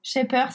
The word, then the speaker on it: Shaper: Mais c'est euh, très intéressant et je ne connaissais pas Shepard, donc euh, je vais aller regarder. Shaper, ça s'écrit S-H-A-P-E-R. Ok, génial.